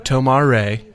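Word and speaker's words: Tomar-Re: So the question is, basically, they showed a picture of Tomar Ray. Is that how we pronounce it? – It is, because Jeffrey Rush is playing him.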